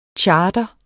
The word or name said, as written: chartre